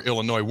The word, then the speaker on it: Illinois: The bad guys won that day, but hopefully the good guys win tomorrow when Nebraska, 22nd ranked, takes on number 24, Illinois, where the S is silent. Do not pronounce that S, Jake.